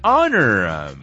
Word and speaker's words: honor: Y ojo con la pronunciación, mi raza, honor. Porque la H prácticamente no suena, ¿ok, guys?